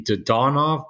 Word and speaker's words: Dadonov: These Russians with this pronunciation stuff, I'm convinced that we pronounce half of them wrong and that the accent should be on the second syllable, like Ovechkin, so that it should be Dadonov.